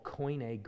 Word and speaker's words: Koine: And that language is called Koine Greek, or Koine means common.